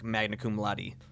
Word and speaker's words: magna cum laude: He won numbers of scholarships and graduated like magna cum laude.